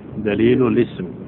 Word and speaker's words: دليل الاسم: هو كلمة اسم همزتها همزة وصل، ففي درج الكلام نقول دليل الاسم، دليل دليل الاسم دون نطق الهمزة.